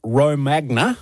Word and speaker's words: Romagna: We tend to put the emphasis on the wrong syllable here. – Or as we would say here, Romagna.